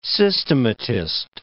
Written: systematist

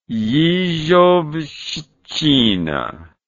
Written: yezhovshchina

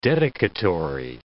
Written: dedicatory

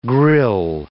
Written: grille

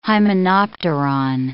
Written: hymenopteron

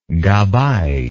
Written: gabbai

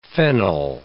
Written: fennel

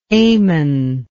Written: Aman

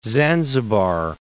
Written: Zanzibar

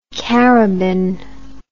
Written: carabin

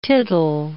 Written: tittle

